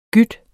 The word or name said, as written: gyde